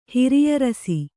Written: ಹಿರಿಯರಸಿ

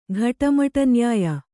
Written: ಘಟಮಟನ್ಯಾಯ

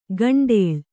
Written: ಕಂಡಾಳು

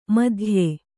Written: ಮಧ್ಯ

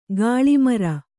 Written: ಗಲ್ಲುಮರ

